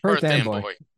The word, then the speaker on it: Perth Amboy: So far, I think it's Perth Amboy. I think I'm saying it wrong because I'm not a Jersey guy. – Perth Amboy. Yeah. Perth. We got it.